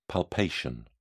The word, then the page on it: palpation: British English